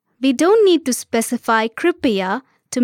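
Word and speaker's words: Kripya (कृपया): This is Kripya, Kripya. Let's break it down by syllable. Kri, pi, ya. Now let's hear it once again. Kripya.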